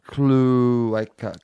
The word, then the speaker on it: cloaca: But in animal anatomy, a cloaca, pronounced cloaca, that's the French. – Cloaca. – Cloaca. I think cloaca.